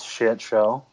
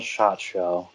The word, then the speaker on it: Sh*tshow: Uh, how do you actually pronounce the name of your book? It is shit show. Okay. That's that's that's how it goes. I know some people call it shot show.